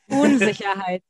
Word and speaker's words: Unsicherheit: Unsicherheit. – Unsicherheit? I probably am way off. Did I say it right? – No, not really. Unsicherheit.